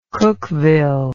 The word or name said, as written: cookeville